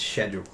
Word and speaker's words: schedule: Can you say it properly? It's schedule. – Schedule.